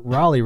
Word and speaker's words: Raleigh: I mean, you know, obviously you couldn't call it Sir Walter Raleigh, but or Raleigh rather, as I mispronounce everything.